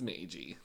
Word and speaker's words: Meiji: You keep telling me to pronounce both letters sometimes. Meiji. – It's Meiji.